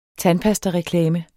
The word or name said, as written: tandpastareklame